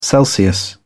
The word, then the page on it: celsius: British English